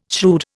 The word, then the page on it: shrewd: Indian